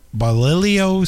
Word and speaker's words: bolillo: It was introduced in the 1980s and consists of hot dog that is wrapped in bacon and grilled, served on, I hope I pronounced this correctly, it's Bolillo style hot dog bun. It's B-O-L-I-L-L-O. – I think that was pretty close, if not right.